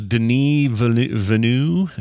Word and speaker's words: Villeneuve: And I think Villeneuve, or how you say his last, somebody told me yesterday it was Denis Villeneuve is how you say his name. – Oh, really? – I'm wow. like, dude, you got too many French hidden syllables in there and stuff. – Really, exactly. Like, we'll take this five-syllable word and just drop it down to two.